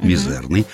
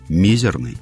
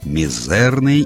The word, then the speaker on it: мизерный: Старые издания говорят, что ударять нужно на второй слог, «мизерный». А вот новые, современные, допускают ударение на первый, «мизерный». Вот.